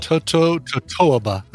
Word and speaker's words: totoaba: Totoaba. I've never heard of totoaba fish. – Or tutoaba. I don't know how you say it. – Totoaba. Two bands. Totoaba. – Totoaba. Exactly.